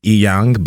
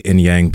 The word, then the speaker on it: Inyang: My last name is Inyang, but I just say Inyang because it's easier for everyone to say with the way my name's spelled.